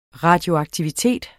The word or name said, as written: RADIOAKTIVITET